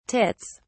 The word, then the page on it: tits: British English